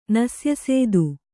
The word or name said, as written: ನಸ್ಯಸೇದು